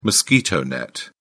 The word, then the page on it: mosquito net: British English